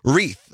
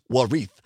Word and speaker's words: wreath: Wreath. There's a silent W to start with. – There is. – W. So let's pronounce it. Wreath. The word of the hour is Wreath. W-R-E-A-T-H.